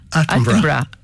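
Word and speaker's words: Attenborough: Από τον Ατένμπορο, ένα άνθρωπο για τον Ατένμπορο, ή πόσο το σωστά η προφορά. – Άτεμπρα.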